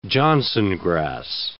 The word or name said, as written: johnsongrass